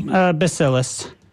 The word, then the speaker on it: Bacillus: How do you pronounce that word? – Bacillus.